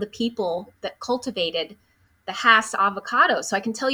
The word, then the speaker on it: Hass: Oftentimes people say Haas, but it's actually Hass.